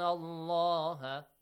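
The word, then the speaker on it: Allah (الله): But when it comes with the letter, with the word Allah or Allahumma, it has to be heavily pronounced if there is a fatha sound before. If it is a kasra sound before, lightly.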